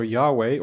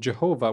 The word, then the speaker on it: Yahweh: The J stands for Yahweh, or the misunderstood pronunciation Jehovah, which was prominent at the time, and this is a personal name for God, Yahweh that is.